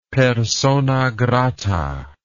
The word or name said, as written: persona grata